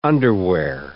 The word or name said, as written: underwear